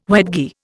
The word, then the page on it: wedgie: Indian